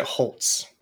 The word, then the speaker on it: Holz: There's not a T in it, but I think that's how he pronounces it. – So Holtz, Holtz is a little embarrassing, I think. So it's not a silent T, it's an invisible T.